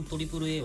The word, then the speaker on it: AAA: ちなみにトリプル A